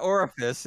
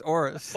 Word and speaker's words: Aorus: Not Orifice, it's Aorus.